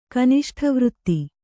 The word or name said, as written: ಕನಿಷ್ಠವೃತ್ತಿ